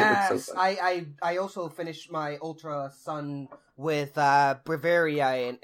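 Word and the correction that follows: Braviary: and that's how you pronounce it? – Breviary.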